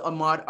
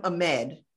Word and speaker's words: Ahmaud: and I hate that it's called the Ahmaud Arbery trial, because he was not on trial. He right. wasn't even there, but the lady that was reporting on it, she mispronounced his name. She called him Ahmed.